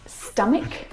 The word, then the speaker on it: stomach: And there are quite a few English words that actually have this same pronunciation of the CH combination. Words like stomach. – Stomach. – And ache. The CH in all of these words is pronounced like a K sound.